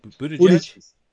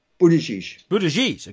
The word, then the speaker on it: Buttigieg: I think how you pronounce that, correct? – Buttigieg. – Buttigieg.